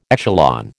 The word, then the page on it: echelon: American English